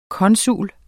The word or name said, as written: konsul